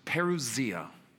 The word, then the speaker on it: parousia: And he took this very important Greek word, pronounced parousia.